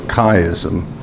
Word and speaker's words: chiasm: It's transliterated CH, but it has a hard pronunciation to it, a k sound. Christ, and that's why it's called a chiasm.